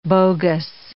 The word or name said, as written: bogus